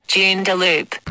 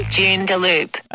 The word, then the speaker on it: Joondalup: I was driving around using my Google Maps, told me to turn left on Joondalup Drive, but she said Joondalup. – Hey? – Joondalup. – That's not right. – Joondalup.